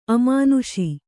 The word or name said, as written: ಅಮಾನುಷಿ